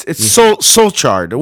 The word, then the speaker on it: Solskjaer: But you are close without saying Kagard on the end of it. – There's no Kagard there. It's Solskjaer. Well, I'm going to call him the soldier.